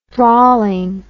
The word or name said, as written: drawlingness